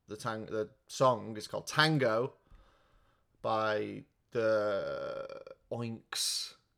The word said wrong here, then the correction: Onyx: Collective. I'm saying that wrong. O N Y X. It's like a fucking metal or something, isn't it? Onyx. Onyx. Okay.